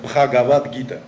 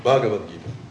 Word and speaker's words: Бхагавадгита: Так же, как мы говорим, есть такое произведение, которое называется по-русски написано «Пхагавадгита». Но на санскрите оно звучит «Бхагавадгита».